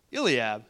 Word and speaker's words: Eliab: And in 1 Samuel chapter 16, verse 6, it says, When they arrived, Samuel took one look at Elab, and thought, Elab, Eliab. How do you say that? Elab, Eliab. Eliab. That's That sounds a lot better. Eliab, yes.